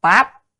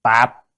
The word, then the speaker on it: táp: Tam là ba, táp chữ này có ba âm, có thể đọc là táp, tạp, và trong kinh A Di Đà là tráp.